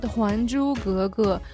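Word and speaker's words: Huan Zhu Ge Ge (还珠格格): Su Youpeng was also known in the public for his role in Returning the Pearl and Guguh. I'm pronouncing that correctly. – Please. – Did I mispronounce that, or? – It's called Huan Zhu Ge Ge. Huan means return by the character, Zhu means pearl, but you don't say return the pearl.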